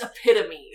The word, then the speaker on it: epitome: You, I fucking said epitome for years. It's epitome. I learned that in high school. That's how it's spelled, damn it.